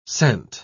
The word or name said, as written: scent